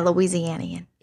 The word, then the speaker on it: Louisianian: A fellow Louisianian. – And that is why I wanted to leave it as a surprise. Obviously, this is a fellow Louisianian. Is that how you say it? – Yeah, Louisianian.